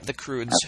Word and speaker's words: Croods: Croods, is that how you say it? – Yep, the Croods.